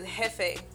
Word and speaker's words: jefe: But that's actually Dominican slang, though, because really how to say boss in Spanish is jefe. So, young jefe, Holmes. – Jefe, yeah. So that's really how you say it in Spanish, but it's Spanish and you have Dominican slang.